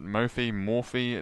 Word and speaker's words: Mophie: I have a really exciting product too, and it's called the Mophie, which is how I pronounce it. Mophie. It's M-O-P-H-I-E. Mophie is how I say it.